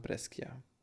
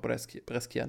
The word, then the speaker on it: Brescia: Geburtstag in Zahlen 16 zu, ich glaube, es spricht man auch Brescia, ne? Nicht Brescia. – Mein Italienisch ist wirklich ganz hervorragend und deswegen werde ich das nicht wiederholen. – Okay, Brescia. Ich bleib dabei, Brescia, zu